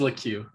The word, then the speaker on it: Lecque: So it is Lecque. Okay. – It is Lecque. – You had me thinking twice for a minute there, and then yeah. I was like, "There's no way." – No. So Jalen Lecque, a really high profile recruit in high school.